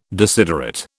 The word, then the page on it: desiderate: British English